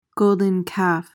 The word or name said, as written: golden calf